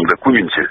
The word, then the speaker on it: документе: Во-первых, для того, чтобы написать то, что написано в этом документе, я подчеркиваю, с ударением на «у», потому что юридическим документом я пока воздержусь это называть, столько времени не надо.